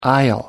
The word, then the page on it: aisle: American English